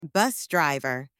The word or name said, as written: bus driver